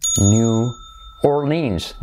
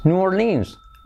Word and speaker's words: New Orleans: New Orleans. New Orleans. New Orleans. Letra clave R, letra clave L. Orleans. New Orleans. New Orleans. Have you guys ever been to New Orleans?